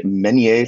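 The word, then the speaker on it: Menier: This is a one-man show called Alex Edelman, Just for Us, that will be playing through February 26th at the Meunier Chocolate Factory. I'm not sure if I pronounced that right. – Yeah, yeah, you did.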